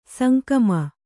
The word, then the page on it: ಸಂಕಮ: sankama